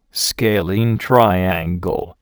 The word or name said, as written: scalene triangle